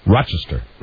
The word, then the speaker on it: Rochester: You don't pronounce the S in it. It's Rochester.